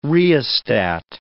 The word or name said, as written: rheostat